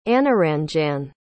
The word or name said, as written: anuranjan